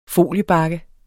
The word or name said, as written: benyt